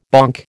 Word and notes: bonk: American English

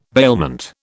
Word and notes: bailment: British English

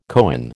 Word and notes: coign: American English

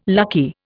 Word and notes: lucky: Indian

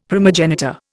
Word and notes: primogenitor: Indian

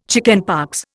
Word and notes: chickenpox: Indian

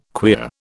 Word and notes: queer: British English

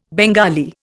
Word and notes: Bengali: Indian

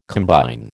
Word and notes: combine: American English

